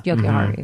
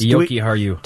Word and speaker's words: Jokiharju: Jokiharju. – Mm-hmm. Yes. – Jokiharju.